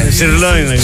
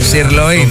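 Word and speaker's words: sirloin: Σιρλόιν. – Σιρλόιν.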